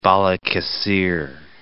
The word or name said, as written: Balikesir